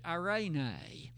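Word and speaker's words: irene (εἰρήνη): I've shared with you the Greek word that is translated as peace, and I've even shared with you a pronunciation of it, irene.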